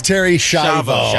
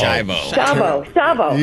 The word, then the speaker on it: Schiavo: Terry Schiavo. – Technically, you're saying it wrong. – Yeah. We might be saying it wrong. You don't know. – It's Terry Schiavo. – Schiavo. – You got it.